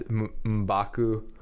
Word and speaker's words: M'Baku: And I really like to see Winston Duke as M'Baku.